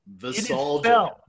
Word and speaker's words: visalgia: So, the word of the day for you is visalgia. – Visalgia.